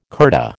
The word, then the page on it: kurta: American English